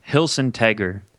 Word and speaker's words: Hilsentager: Hilsentager. – Hilsentager. Okay. – You got it. Yeah. – It's kinda, it sounds kind of like Schwarzenegger. Has anyone ever said that to you before? – That it sounds like Schwarzenegger?